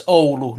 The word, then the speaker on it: Oulu: Oh yeah, it's Oulu, not Ulu. – Thanks. – All right. Ulu. Noted. Noted. I'll slap myself on the wrist. – Is that Oulu spelled O-U-L-O? – It's O-U-L-U.